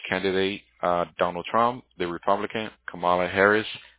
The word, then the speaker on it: Kamala: Tell me how you pronounce her name again. – Kamala Harris. – Kamala. – Oh, Kamala. Man.